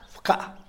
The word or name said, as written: pkaa